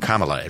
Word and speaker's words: Kamala: A buddy of mine was just telling me, do you know how she explained how you pronounce her name? Kamala. It rhymes with Mamala.